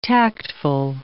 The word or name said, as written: tactful